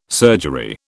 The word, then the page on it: surgery: British English